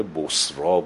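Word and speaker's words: بصری: دوباره میفرسته به همون سمت، منتها به یک منطقه دیگری که اسم اون منطقه بصرا بوده، مثل بصره هست ولی تلفظش بصراز، و اونجا یک حاکمی داشته به نام شرحبیل که اینم مسیحی بوده.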